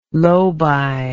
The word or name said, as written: lobi